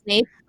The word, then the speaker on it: Snape: Snape rhymes with snake.